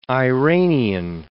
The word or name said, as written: Iranian